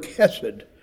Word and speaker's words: chesed: That word comes from the Hebrew word chesed, C-H-E-S-E-D. That's an anglicized pronunciation. That's probably not how a Jew would pronounce it, but it chesed.